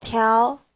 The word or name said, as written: tiao2